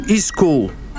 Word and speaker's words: school: Um erro bem comum é usar tipo um i no início, tipo school, onde a pronúncia correta deveria ser apenas o s.